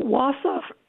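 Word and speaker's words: Wausau: So wasaw, wasaw, for example, comes from the Ojibwe word for far away or distant. How would you say that yourself? – I would say wasa.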